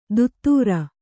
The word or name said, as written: ದುತ್ತೂರ